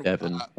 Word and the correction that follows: Davon: Okay, Davin.